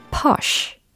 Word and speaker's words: posh: posh, posh. 요거거든요. 짧죠? 예. 그래서 철자 알려드리면 P, O, S, H라는 철자입니다. 어, 영국식 발음으로 한다면 뭐 posh 이렇게 되겠죠. 예, 모음에 살짝 차이가 있을 겁니다.